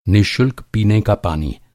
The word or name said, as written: निःशुल्क